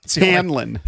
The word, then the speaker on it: Hanlon: Harry Hamlin's razor. – Hanlon. – Hanlon. Okay. Glenn Hanlon's razor.